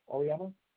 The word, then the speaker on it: Auriemma: It's Joe Auriemma. – How do you spell that last name, Joe? – A-U-R-I-E-M-M-A.